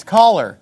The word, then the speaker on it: choler: It's not choler, it's caller. And choleric is a very common word. And I learned the word caller, C-H-O-L-E-R, in elementary school on a vocab quiz.